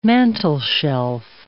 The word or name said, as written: mantelshelf